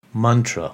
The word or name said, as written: Mantra